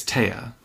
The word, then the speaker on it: Tea: And if I hadn't done Audible, I would have pronounced her name T the whole time. It's Taya. – It's literally spelled T-E-A.